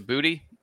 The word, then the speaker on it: Boutte: one of the reasons he's disappointing is because his pronunciation of his name changed to Booty, and that's fine, but I hate when that changes.